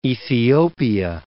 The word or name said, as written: Ethiopia